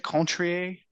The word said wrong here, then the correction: Cointreau: Cointreau and lime juice.